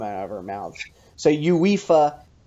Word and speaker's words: UEFA: Is that how you're supposed to say it or is that just how the fancy British announcers say it? – They it's just that's the way they pronounce it. They just say UEFA.